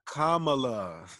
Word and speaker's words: Kamala: I said that right, didn't I? – Kamala. – Oh, shit, damn, y'all. I still fucking it up, y'all. Kamala. Kamala Harris.